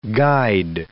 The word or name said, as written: guide